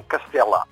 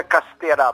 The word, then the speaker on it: Castela: Os japoneses pronunciam Castela. – Castela. – Castela. – Ok. – E há quem diga Castera, porque os japoneses não veem diferença entre os erros e os L's.